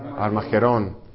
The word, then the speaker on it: Armagedón: Armagedón, con acento al final.